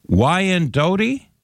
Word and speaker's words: Wyandotte: And then, boy, you folks in Kansas are going to be correcting my pronunciation on this, I'm sure. It's Wyandotte. – That sounds about right.